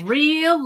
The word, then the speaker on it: Areel: Like, a real looker. – She's a real looker. Exactly. Except it's spelled A-R-E-E-L. So like A-real.